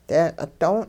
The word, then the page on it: deeqi' don': hupa